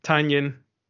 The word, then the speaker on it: Tynion: I think his name is actually pronounced Tanyan.